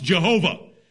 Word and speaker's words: Jehovah: that when pointed up with European vowels is pronounced Jehovah.